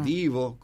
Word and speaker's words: Devo: Divo, con B corta, se escribe Devo.